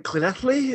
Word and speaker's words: Llanelli: It's spelled Llanelli, but it's it's is it Clinethley? Is that how we actually say it? – Yes, yes, hey, that was a very good pronunciation.